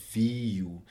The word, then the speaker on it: Viu: And the next issue, so SABC has recently uh, signed uh, a deal with uh, a streaming service called Viu.